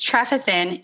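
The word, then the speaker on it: Trefethen: It's called Trefethin. I said it correctly earlier. It's Trefethin.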